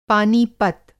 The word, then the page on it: पानीपत: پانی پت